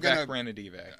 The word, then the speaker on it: Vivek Ranadive: And Vivek Ranadive. – Oh, wow. That was good. Yeah. Good pronunciation, too, sir.